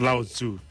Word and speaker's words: Lao Tzu: Like Lao Tzu.